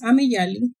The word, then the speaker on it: Ameyali: I mean, my name is Ameyali, which pronounced in Spanish.